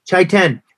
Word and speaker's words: Chaitén: How do you say that one? – Chaitén.